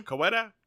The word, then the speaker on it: Coweta: This one is called Murder in Coweta County. Mm-hmm. Coweta? Like they pronounce this Coweta County.